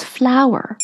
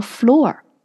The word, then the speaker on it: flour: OK，so、 it's flower，跟花儿一样，不是跟地一样。 You know, it's um F L O U R，flower flower，它发音呢就是跟花儿是一样的。 Very often 我会听到身边的朋友说 floor。